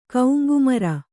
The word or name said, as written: ಕೌಂಗುಮರ